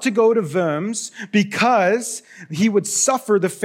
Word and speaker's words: Worms: On April 14th, 1521, Martin Luther was on his way to the Diet of Worms, not the Diet of Worms as it's spelt, it's the Diet of Worms, it's German.